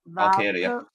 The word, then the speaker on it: Valkyria: The women's title match, Roxanne Perez versus Lyra Valkyrie. I can't even say her name. Lyra. – Valkyria.